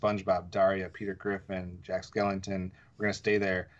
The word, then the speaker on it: Skellington: And for some reason, I think I said Jack Shellington instead of Skellington. So let me correct myself.